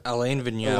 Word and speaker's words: Vigneault: Alain Vigneault. – Alain Vigneault.